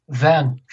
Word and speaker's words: Van: Ron, his last name is Van Herpen, V-A-N. Am I saying that right? – Van. Van. – Okay. – Yeah. – Ron Van Herpen. It's V-A-N-H-E-R-P-E-N.